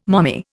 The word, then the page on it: mommy: Indian